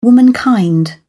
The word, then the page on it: womankind: British English